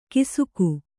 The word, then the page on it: ಕಿಸುಕು: kisuku